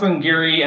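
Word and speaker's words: Glen Garioch: Yeah, it's pronounced Glengarry, and it looks like Glengarrick.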